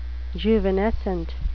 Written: juvenescent

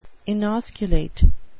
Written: inosculate

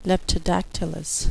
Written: leptodactylous

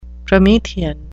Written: promethean

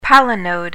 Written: palinode

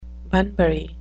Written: bunbury

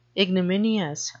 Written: ignominious

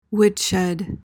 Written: woodshed

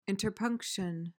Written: interpunction

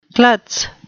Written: klutz

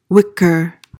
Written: whicker

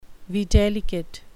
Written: videlicet

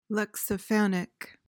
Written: lexiphanic

